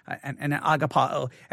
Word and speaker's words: agapao: Agapao is the way it is. And I know everyone says agape, and that's okay.